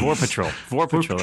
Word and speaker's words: Vorpatril: And before that, I was reading the Captain Vorpal. I can never pronounce any of these names. – Vorpal Patrol. That's all it is.